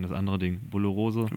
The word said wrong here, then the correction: Borreliose: Bo Bo Borreliose?